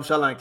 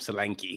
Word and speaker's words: Solanke: I'm glad that you said his name properly, because the way they'd be saying his name, Solanke. That is not how you say his name. Please stop that.